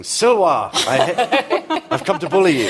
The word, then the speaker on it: Silva: So my dad's nickname at school, some of you will know this, was Silva, Latin for wood or forest, except that in the English tradition, you didn't pronounce the V's. I don't know if they still do that in the States. So you pronounce the V's as W's. So in fact, his nickname was Silwa.